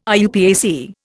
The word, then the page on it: IUPAC: Indian